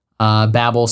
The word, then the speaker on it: Babel: Babel spelled like Babbling Brook, not like the Tower of Babel.